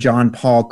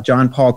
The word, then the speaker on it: Jon Paul: And you can just Google my name, John Paul Krimi. The last name is spelled C-R-I-M-I. John Paul is spelled J-O-N-P-A-U-L. John Paul Krimi.